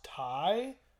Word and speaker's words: Tay: Serena Deeb versus okay, her name is spelled out as Tay. – I think it's Ty. – But I think it's pronounced Ty.